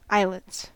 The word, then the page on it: eyelets: American English